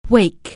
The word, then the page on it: wake: anglais